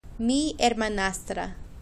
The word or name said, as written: mi hermanastra